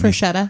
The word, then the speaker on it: Freshetta: So I chose, I'm going to butcher this name, so someone might need to help Freshetta.